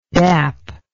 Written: dap

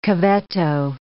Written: cavetto

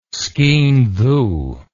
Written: skean dhu

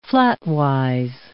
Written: flatwise

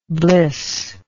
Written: blissless

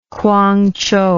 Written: Kwangchow

